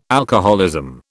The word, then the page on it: alcoholism: British English